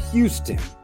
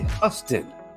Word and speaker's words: Huston: Now, many pronounce that as Houston. And what I learned is it's actually Huston, named after Samuel Huston.